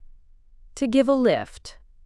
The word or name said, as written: to give a lift